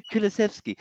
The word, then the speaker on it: Kulusevski: I think Kulusevski? I can't even say his name. – Kulusevski.